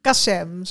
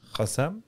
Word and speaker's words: Kassem: Galit Kassem. Zo moeilijk Ook oh, is het. gewoon met een K. Galit Kassem. – Gassem? – Ja, die werd ook in de groep gestuurd.